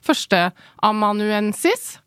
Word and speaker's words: førsteamanuensis: Du er jo da førsteamanuensis, er det riktig uttalt? – Riktig. Ja, veldig bra.